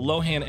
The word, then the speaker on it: Lohan: While Lohan emphasized the O in her last name, people commonly stress the A. So it's Lohan.